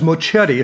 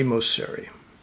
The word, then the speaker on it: Moceri: But the last name is Mochetti. If it's pronounced correctly, I've got Sicilian ancestry. So I find in Europe people pronounce it the correct way. But in America, I'm definitely Mosiri.